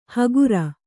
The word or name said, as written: ಹಗುರ